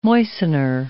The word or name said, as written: moistener